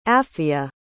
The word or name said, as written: apphia